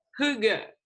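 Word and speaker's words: hygge: C'est ça ça reprend l'esprit Heige, si je le prononce bien. Ah, attends, reprononce-le parce qu'on ne l'a pas entendu malheureusement. – Hugue. – Hugue, ok.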